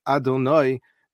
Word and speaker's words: Adonai: But the name that we call the Shem Havaya, the Shem HaMafurash, which is written Yudke Vavke and pronounced Adonai, that is the word that we find primarily used in the Torah, describing God.